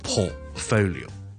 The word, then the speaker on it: portfolio: 因为他成日听到人读呢 portfolio。正确发音呢当然是 portfolio。 portfolio 是一个 O 音啦 ,O,O,P,P,P。跟着一个 T 在这里。